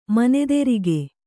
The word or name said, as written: ಮಧುರಿಕೆ